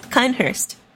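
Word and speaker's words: Cainhurst: Now I'm saying Kanehurst, look what you've done to me. – Yes, finally! Because that's the correct way to pronounce it. Kanehurst.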